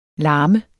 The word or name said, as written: larme